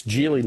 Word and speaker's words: Geely: So it's Geely, not Geely, even though it's spelled obviously Geely, but they pronounce it Geely.